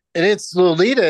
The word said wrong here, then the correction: Loleta: isn't it? – You keep calling it that because I think it's Loletta. – Yeah, it's spelled Loleta.